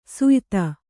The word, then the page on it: ಸ್ಯೂತಿ: Kannada